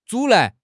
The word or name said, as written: 阻拦